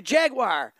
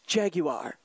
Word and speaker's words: Jaguar: And he says, great fun show, even if Brad can't pronounce Jaguar. Now, I just wanted to keep it down and dirty, man. It's California Jaguar. – Jaguar.